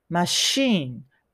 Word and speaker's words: machine: で、ここは綴りは CH なんですが、 SH と同じ発音だから、マシーン、マシーンですね。なので、 I'll show you how to use this machine になりますね。